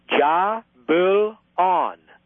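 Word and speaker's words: Jahbulon: Here's how to pronounce, Todd, the Yod-Heh-Vav-Heh, or Y-H-V-H, or W-H, from which we get Yahweh. Here's how to pronounce it in Masonry, in the seventh degree York Rite. Ja-Bul-On. And we are told that Yah means Yahweh, Bul means Baal, and On means Osiris.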